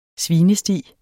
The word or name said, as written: svinesti